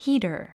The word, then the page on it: heater: híːtər; ヒータァ